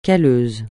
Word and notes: calleux: francais

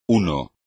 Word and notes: uno: espagnol

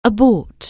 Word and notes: abort: anglais

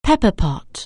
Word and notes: pepper pot: anglais

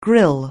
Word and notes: grille: anglais

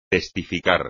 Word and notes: testificar: espagnol

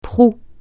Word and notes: proue: francais

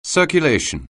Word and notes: circulation: anglais

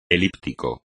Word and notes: elíptico: espagnol

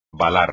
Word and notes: balar: espagnol

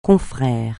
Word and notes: confrère: francais

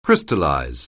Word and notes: crystallize: anglais

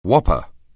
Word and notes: whopper: anglais

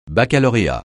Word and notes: baccalauréat: francais

